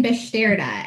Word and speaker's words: Beszterda: And our guest today is actually a fellow planner, Logan Besterda.